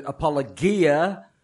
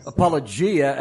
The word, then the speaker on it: Apologia: And now that James is actually a co-elder there of Apologia Church in Mesa, Arizona, he has caved in and no longer pronounces it Apologia. And he pronounces it Apologia, as they have been pronouncing it all along.